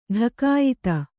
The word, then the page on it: ಢಕಾಯಿತಿ: ḍhakāyiti